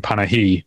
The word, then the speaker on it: Panahi: It's actually because of Three Faces that I know how to pronounce his name, Panahi, because people call him Mr. Panahi roughly 500 million times in Three Faces.